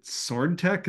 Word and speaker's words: SwdTech: Is it Sword Tech? Is that like the intent? SWD? – Yeah.